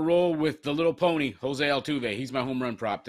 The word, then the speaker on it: Altuve: I feel like you could have done a little bit better with the Jose Altuve pronunciation. – Jose Altuve. – There you go.